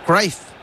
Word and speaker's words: Cruyff: Pero Quique Guas siempre pronunciaba Johan Cruyff. ¿No? – Cruyff. – Lo pronunciaba como teóricamente lo pronunciaban los holandeses.